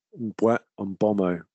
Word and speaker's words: Mbeumo: I think he came out on Sky and he said it's Mbeumo. Mbeumo? Anyway. – Mbeumo. – Pronounce every syllable.